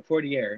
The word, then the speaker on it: Poirier: Is it Poirier or Poirier? Am I saying that wrong? – You can just say Poirier. You don't got to roll your tongue.